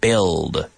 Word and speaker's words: build: Well, it's time to talk about the silent U, everybody. The silent U of words like build.